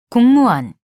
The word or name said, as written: 공무원